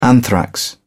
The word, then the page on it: anthrax: British English